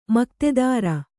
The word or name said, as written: ಮಕ್ತೆದಾರ